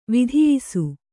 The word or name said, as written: ವಿಧಿಯಿಸು